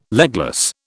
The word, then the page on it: legless: British English